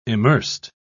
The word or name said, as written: immersed